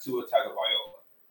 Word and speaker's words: Tua Tagovailoa: And I'm talking about quarterback Tua Tagovailoa. Yes, I'm one of the only niggas on the planet that can pronounce his name correctly.